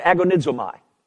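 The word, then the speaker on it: agonizomai (ἀγωνίζομαι): Um, you pronounce that agonizomai. Does that sound like anything?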